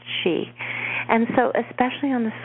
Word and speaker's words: Sidhe: and it's pronounced the she, and they are beings that are descended from the stars as we are, but they're more like the fairy beings, and we went more materialistic, but at one time we were the same. We have the same ancestors, and you spell it S-I-D-H-E. So if you look up on Amazon, S-I-D-H-E, I'd never heard of it until about 8 months ago.